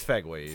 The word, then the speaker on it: Fegley: It's F-E-G-L-E-Y for those who are listening. – Figley Brewworks. – It's Fegley's. – Fegley. That sounds more like it.